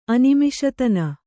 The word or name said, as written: ಅನಿಮಿಷತನ